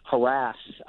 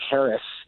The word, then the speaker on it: harass: I mean, you look at the word which now is standardly pronounced harass. A couple decades ago, it was almost only pronounced Harris. And over time, the harass pronunciation became more and more prominent. And by now, it's the more favored pronunciation.